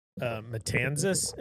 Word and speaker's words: Matanzas: How do I say the name? I asked how to pronounce his name earlier, and he said, however he says it, you're for sure gonna correct me. – Oh boy. – Matanzas Inlet. – That's correct.